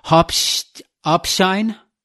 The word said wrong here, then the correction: Hauptschein: Hauptschein, very close. Yeah, Hauptschein.